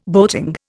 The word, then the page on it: boating: Indian